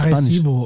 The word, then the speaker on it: Arecibo: Erkipo? ins All. – Es ist A ah, Arecibo. Spanisch.